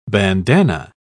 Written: bandanna

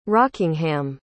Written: rockingham

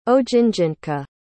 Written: ojinjintka

